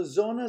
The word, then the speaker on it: Sonne: So Sonne is a double N, and double consonants in German, we pronounce both of them, so it makes the N a little bit longer.